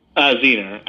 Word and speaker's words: Zener: Zener. Right? Am I saying that correctly? – Zener, actually. Zener. I know it's hard to pronounce, but like we went with that name because it was based off of the Zener cards.